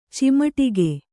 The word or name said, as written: ಚಿಮುಟಿಗೆ